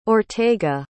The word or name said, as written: ortega